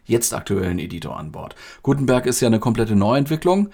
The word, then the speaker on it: Gutenberg: Gutenberg, man darf das ruhig deutsch aussprechen.